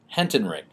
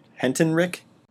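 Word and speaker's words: Hentenryck: I've heard it pronounced so many different ways. – Kevin Van Hentenreich? – Hentenreich. – Hentenreich? – Yes, I believe that's right.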